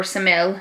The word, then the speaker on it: Semele: He slept with Semele, or Semele, however you want to pronounce it. It's S-E-M-E-L-E. The princess of Thebes. T-H-E-B-S. – Thebes. – T-H-E-B-E-S.